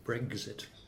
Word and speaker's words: Brexit: And I'm very pleased to hear that when you talk about Brexit, you don't pronounce it with the letter G in the middle, which so many journalists tend to do. Brexit.